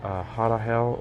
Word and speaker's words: Harahel: This next Harahel, or it's spelled H-A-R-A-H-E-L, Harahel.